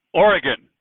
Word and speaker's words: Oregon: Oregon. Like gun. – Oregon. – Oregon. – Okay. Yeah, okay. That's how I try to say it, but every now and then I hear someone say Oregon, and it messes me up.